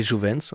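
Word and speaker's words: Jovents: J'adore le Massilia Sound System, j'adore Moussuté et les Jouvents, c'est comme ça qu'on prononce ? – Et les Jouvents. – J'ai les Jouvents, pardon, pas de nasal.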